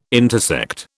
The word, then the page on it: intersect: British English